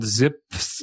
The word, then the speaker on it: Zipf: And he recently put out a video about a concept called Zipf's Law. It's hard to pronounce because it's spelled Z-I-P-F. Zipf, I guess.